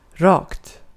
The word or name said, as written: rakt